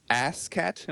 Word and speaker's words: Ass Cat: Since then, Matt's continued to perform improvisational sketch comedy with UCB Theater and has released a DVD entitled Ass Cat. Am I saying that correctly? – That's right. Don't be afraid to say the ass and ass cat. – Emphasize the ass.